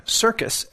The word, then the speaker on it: Sircus: It's by Mark Circus, S-I-R-C-U-S. It's pronounced like a circus, like a town circus, but it's spelled with an S, S-I-R-C-U-S. Again, it's called Transdermal Magnesium Therapy.